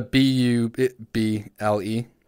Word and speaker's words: Bublé: I would have expected something else with a name called Buble. – It's a B U B L E. So it looks like bubble, but with one less B. And it's pronounced Buble.